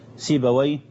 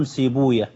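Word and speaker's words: سيبويه: أهل اللغة ينطقونها كما أنطقها الآن، سيباوي باباوي نفطاوي بفتح الواو وإسكان الياء، وأهل الحديث لا ينطقونها كذلك، إنما ينطقونها يفرون من نطق وي، لأنهم يرون حديثا ضعيفا لا يثبت إسناده بأن وي اسم شيطان. ولذلك وحتى وإن ضعف الحديث فإن بعض أهل العلم أو كثير منهم صراحة يحتاط يعني، ولذلك ينطقونها على خلاف ذلك، فيقولون سيبويا وبابويا ونفطويا وحمويا وهكذا، فرارا من أن يقولوا ويه يعني.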